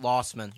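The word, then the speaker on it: Losman: Luzman? Like, wait, what? – Luzman. – I feel like I'm pronouncing it wrong. – Lossman. JP Lossman.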